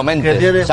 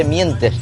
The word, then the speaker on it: mientes: ¿Sabes que no se dice mentes? Se dice mientes. Que el verbo es mentar, pero no se dice mentes. Y lo aprendí ayer en un programa de la radio. De la tele, dice. No se dice mentes. Se dice no lo mientes. Con la I.